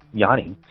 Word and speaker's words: Yanni: It's just that the J in Spanish is sometimes pronounced as a Y. And I don't know, Johnny was a common name, and I just became Yanni.